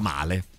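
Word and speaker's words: Mäle: In realtà si pronuncia comunque Male in italiano, come lo diciamo in italiano.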